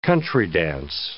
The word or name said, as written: country-dance